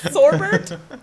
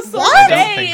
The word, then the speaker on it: sorbet: Sorbert? – Okay, so there's sorbet and sherbert, but there's also sorbert, right? – No, sorbet is so. Just pronounced. – No, I don't think S-O-R-B-E-T is how sorbet is spelled.